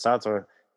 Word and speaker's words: Sartre: I guess that's Sartre. I assume you wouldn't pronounce the E at the end. That wouldn't be very French.